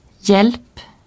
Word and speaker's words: hjälp: Le H est muet devant J. – Hjälp, jul.